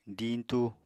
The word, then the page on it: diñtu: apatani